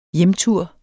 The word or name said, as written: hjemtur